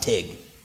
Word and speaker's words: Teague: Tiny little country church in Teague, Texas. Pronounced Tig. They played Little Lig and Tig.